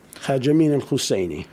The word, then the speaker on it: Haj Amin al-Husseini: Haj Amin al-Husseini.